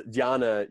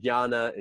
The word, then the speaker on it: Jnana: And then the last is Dhyana Yoga, which is spelled J-N-A-N-A. And that J-N, it's similar to like our word knowledge starts with that K-N.